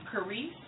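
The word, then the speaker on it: charis (χάρις): or the Greek, I'm sorry, is and hard to pronounce, but it's charis. That's C-H-A-R-I-S. Charis.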